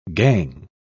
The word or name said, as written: gang